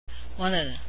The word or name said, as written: wanted